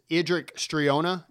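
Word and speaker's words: Eadric Streona: I think he's sort of the darker element of it, but this guy's name is Idric Striona. Am I saying that right? – Right.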